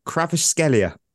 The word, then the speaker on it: Kvaratskhelia: So I'll just say it's Kvaratskhelia.